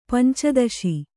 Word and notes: ಪಂಚದಶಿ: panca daśi

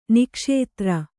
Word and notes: ನಾಗಾಸ್ತ್ರ: Kannada

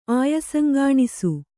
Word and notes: ಆಯಸಂಗಾಣಿಸು: Kannada

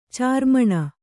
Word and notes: ಚಾರ್ಮಣ: cārmaṇa

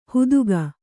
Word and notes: ಹುದುಗ: huduga

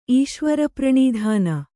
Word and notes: ಈಶ್ವರಪ್ರಣೀಧಾನ: Kannada

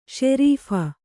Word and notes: ಸರಾಫಿ: sarāphi

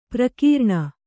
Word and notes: ಪರಕರಣ: parikaraṇa

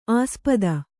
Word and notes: ಆಸ್ಪದೆ: āspade

